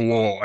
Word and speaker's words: Lowell: When it's pronounced Lowell.